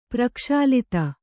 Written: ಪ್ರಕ್ಷಾಲಿತ

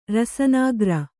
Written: ರಸನಾಗ್ರ

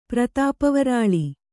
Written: ಪ್ರತಾಪವರಾಳಿ

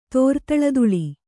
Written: ತೋರ್ತಳದುಳಿ

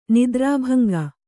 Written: ನಿದ್ರಾಭಂಗ

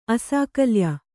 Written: ಅಸಾಕಲ್ಯ

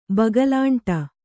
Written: ಬಗಲಾಂಟ

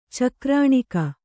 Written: ಚಕ್ರಾಣಿಕ

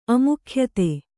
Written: ಅಮುಖ್ಯತೆ

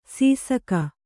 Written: ಶಶಕ

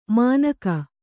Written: ಮಾನಕ

